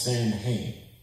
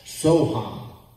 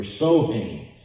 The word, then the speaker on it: Samhain: You realize this goes all the way back to the festival of, it looks like it's pronounced Samhain. It's actually pronounced Sohan or Sohain. It's spelled S-A-M-H-A-I-N, the festival of death.